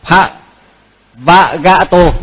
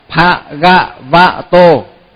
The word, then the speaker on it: Bhagavato: Cái chữ đó là nhiều người hay đọc nhầm lắm. Nhiều khi là pha vạ gạ tô, tức là đọc thật ấy, mà quen rồi bây giờ cứ đọc hoài ấy, mà phải đọc, phải phát âm cho thật đúng là pha gạ vạ tô.